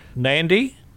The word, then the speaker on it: Nadi: Okay, so to get there, you fly to Nadi? – Yep, that's correct. – Okay, yep. which is spelt with only one N. There's no, it's N A D I.